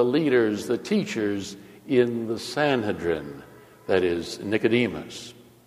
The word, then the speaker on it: Sanhedrin: That would be the Sanhedrin, or some might pronounce it the Sanhedrin,